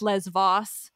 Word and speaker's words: Lesvos: Or then I had the people who were like, it's pronounced Lesvos. And I was like, I know it is in modern Greek.